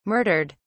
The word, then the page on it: murdered: British English